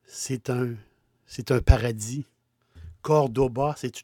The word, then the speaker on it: Córdoba: En français, on prononce Cordoue. Moi, j'aime pas ça dire Cordoue. C'est Cordoba. Et on met l'accent sur le premier O, Cordoba.